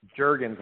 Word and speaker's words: Jergens: Jergens, like the lotion.